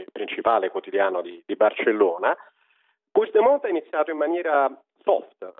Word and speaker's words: Puigdemont: Mi dici tra l'altro come si, visto che tu sai bene il catalano, come si pronuncia il suo cognome? – Si pronuncia Puigdemont. – Puigdemont, è scritto Puigdemont, ma Puigdemont, vediamo se me lo ricordo, va bene.